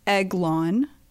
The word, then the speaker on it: Aiglon: Eglon, and this is spelled Aiglon.